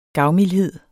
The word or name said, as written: gavmildhed